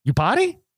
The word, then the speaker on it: Iupati: Iupati? – That's exactly how you pronounce it.